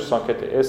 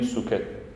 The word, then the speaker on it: socket: آره ساکت همون سوکت انگلیسی تلفظش ساکت SOCKET ساکت ما میگیم سوکت به فارسی.